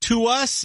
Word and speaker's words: TWS: Baby, you got, yeah, you got, yeah, you got, yeah, you got, you got, you got, you got, group is spelled TWS, but that's not how you say it. It is pronounced to us, and yeah, here they are, to us.